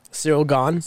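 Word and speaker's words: Ciryl Gane: How do you say his name? – Cyril. – Cyril Gaon. – Cyril Gaon. Jose Aldo, dude? – Yeah, Jose Aldo and Pedro Munhoz.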